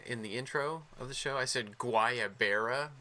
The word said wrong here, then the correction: Guayabera: Very white boy of me. – Mm. I thought it was like guava something. So no, I completely messed it up. – It's Guayabera. That's how you pronounce it.